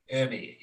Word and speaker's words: Ermey: R. Lee uh, Ermey, yeah, that's the way you pronounce it. R. Lee Ermey right.